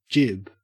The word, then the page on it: jib: Australian English